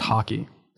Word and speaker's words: haki: Is he learning haiki for the first time? I know Rayleigh hockey. Taught him a lot. – It's pronounced haki. Yes.